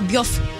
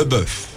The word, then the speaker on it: bœuf: Se și, mai uh, pronunță și bef, salată bef sau bef. – Sau da. biuf. Sau biof. – Biof, da. Le bœuf.